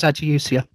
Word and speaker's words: ageusia: So I think it's called ajusia or agusia. Do you know how to say it? – Yeah, it's ajusia.